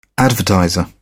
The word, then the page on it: advertiser: British English